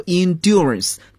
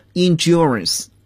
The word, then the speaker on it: endurance: 在英国英语中呢，我们可以读作 endurance，但是在美国英语中呢，我们发音就比较浊化一点了，endurance。